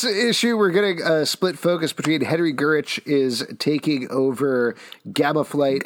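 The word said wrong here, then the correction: Gyrich: I think it's Gyrick. Yeah, it's a Gyrick? Have I been pronouncing it wrong my entire life? – Yes.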